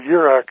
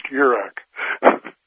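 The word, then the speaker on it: Jurek: Yeah, Yurok. It's spelled funny. It always says in his email signature, pronounced Yurok. It's spelled with a J, you know.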